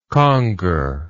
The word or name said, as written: conger